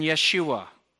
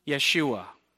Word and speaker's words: Yeshua: Number one, and actually, the name they would have heard would not have been Jesus, it would have been Yeshua. Yeshua. So if you ever hear anybody talk about Jesus as Yeshua, they're saying it properly.